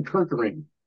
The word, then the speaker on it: Kerkering: I don't know if you saw him, Matt uh, uh, Orion Kirk. Uh, let me spell pronounce his name correctly. Orion Kerkering.